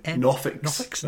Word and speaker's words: NOFX: I'm like, how do you pronounce that? – Oh, you put it together. – You didn't know. – I thought you were going to be like, what? NOFX? – NOFX? – Nofix. – Nofix, I think, was one.